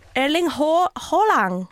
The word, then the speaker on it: Haaland: Du kan säga å. Erling Haaland. Haaland.